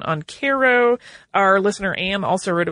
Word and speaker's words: Cairo: He said, to hear you mention that the Sultana stopped in Cairo, and he says the locals pronounce it Cairo, Illinois, was interesting.